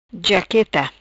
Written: Jaqueta

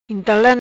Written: Intala

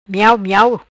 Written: Miao miao

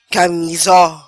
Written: Camessoo

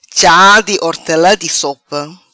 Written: Cha Di Hortelam Di Sopa

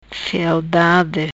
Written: Frialdade

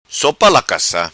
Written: Sopa Lacassa

